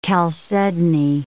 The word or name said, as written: chalcedony